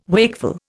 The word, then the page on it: wakeful: Indian